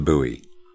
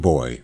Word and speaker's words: buoy: It's B-O-U-Y, but you say buoy. – Okay. – I was saying boy over and over again. – It should be buoy, but it's not.